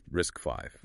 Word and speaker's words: RISC-V: Enter the Rebel RISC-V, pronounced RISC-V.